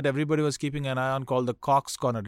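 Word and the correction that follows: Copse: Did I say it right? – It's COPS, Cops.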